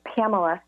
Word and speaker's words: Pamela: And so my name is Pamela,